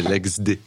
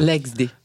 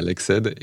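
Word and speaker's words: LEXD: Non, je souris parce que c'est l'ex-D. L-E-X-D, ah. mais c'est pas grave. L'XD. L'XD, sorry. – Non, mais en fait, personne n'arrive à le prononcer. Je même faire un petit aveu au départ, quand je l'ai fondé, j'appelais ça l'XED.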